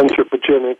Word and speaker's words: anthropogenic: So you also talk about the various kinds of disturbances that are out there, natural disturbances and something you call anthropogenic. Am I saying this right? Anthropogenic? – Yeah, anthropogenic, human-made disturbances.